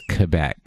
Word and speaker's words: Quebec: No, it's Quebec.